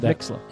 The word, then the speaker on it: Mixlr: Yeah, M-I-X-L-R. – M-I-X-L-R. – App you're going to look for, yeah. – So I said Mixlr. – Mm-hmm. Well, some people might spell it L-E-R, Mixlr. – Oh, no, it's Mixlr.